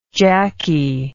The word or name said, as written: jacky